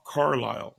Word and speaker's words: Carlisle: Eh, eh, eh, el, cantante se llama Bob Carlyle, se escribe Bob Carlisle, Carlisle, Bob Carlisle.